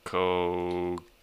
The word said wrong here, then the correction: Cacique: Kosike. – I don't know. – Yeah, that's how it looks, but it's pronounced like... It's pronounced kind of white. – Cacique. – Let me see pronunciation. You know, I see. I already googled it. I knew. It's just cacique.